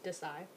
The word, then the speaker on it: Desai: So they know my name is pronounced Kiera, and then just Desai, which I feel like that one's a little bit more simple.